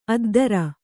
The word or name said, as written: ಅದ್ಧೂರಿ